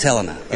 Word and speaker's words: Helena: So you're in Helena, is that how you say it, right? Helena, right? – It is Helena, yes.